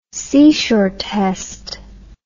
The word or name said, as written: seashore test